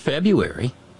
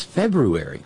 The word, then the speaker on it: February: But I mention all that because of all the month names we have, February, we don't take any time with that R in the middle of the word. February. It's not February. That's not a silent R. It's February.